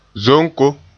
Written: zonko

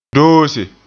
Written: doose

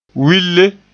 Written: wille

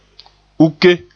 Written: uke

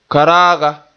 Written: karaaga

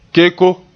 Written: keko

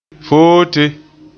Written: foote

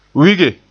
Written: wige

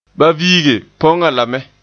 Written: viige

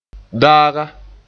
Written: daaga

